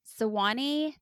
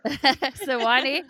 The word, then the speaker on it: Sewanee: He graduated from Sewanee. Did I pronounce that right? – Yeah. – Sewanee, the University of the South, with a Bachelor of Arts in Economics.